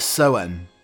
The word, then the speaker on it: Samhain: Some historians believe that the modern Halloween is a descendant of the Celtic holiday Samhain, or again, I pronounce it completely incorrectly, it's Soen it, but it's spelt Samhain.